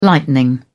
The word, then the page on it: lightning: British English